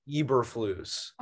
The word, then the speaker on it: Eberflus: First thing, most important, I know now that it is pronounced Matt Eberflus, not Eberflus.